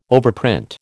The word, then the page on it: overprint: American English